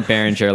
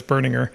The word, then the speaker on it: Berninger: I always said Behringer, like the company. – Yeah, that's what I thought it was too, but it's Berninger.